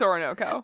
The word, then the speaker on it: Orinoco: The first single off the album, Orenko Flow. – Orinoco Flow, there, Ashley. There it's you go. Orinoco. – Oh, okay. I stand corrected. – Yeah, you said that wrong, Ashley. It's Orinoco.